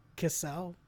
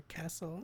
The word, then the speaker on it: Cassel: It's like Vincent Cassel or Castle. Yeah. How do you say his name? – I say it Cassel, but.